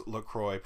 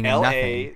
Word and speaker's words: LaCroix: LaCroix, it rhymes with LaJoy.